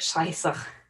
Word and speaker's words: Scheiße: Is it like S-H-E-I, that weird B thing, and then an E? – Probably. – Yeah, it's the German word for bullshit. So you pronounce it Scheiße.